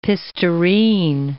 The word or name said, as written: pistareen